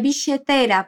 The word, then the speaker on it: billetera: A segunda coisa muito típica da fala de muitos argentinos e argentinas é a pronúncia dos dois L's com o som de SH. Por isso, a palavra billetera, que significa carteira, é bicheteira para a Ana.